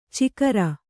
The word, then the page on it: ಚಿಕರ: cikara